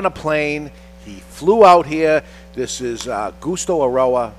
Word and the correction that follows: Justo: I think it's pronounced Justo. – Justo. – Justo? – Justo. – Or at the very least, if you want to go completely Anglo on it, you say Justo, but you can't change a J to a G.